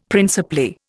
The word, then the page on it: principally: Indian